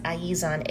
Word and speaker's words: Ayizan: Ayizan, or I think it's pronounced Ayizan, A-Y-I-Z-A-N, her name.